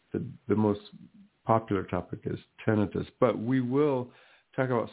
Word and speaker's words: tinnitus: A lot of people somehow for some reason pronounce it tinnitus, which is actually the incorrect pronunciation, tinnitus.